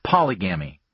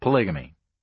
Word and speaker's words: polygamy: The third mating system is polygamy, sometimes pronounced polygamy, from the Greek polis, meaning many, it's where we get the word politics, and again gamos, meaning marriage.